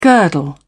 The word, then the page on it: girdle: British English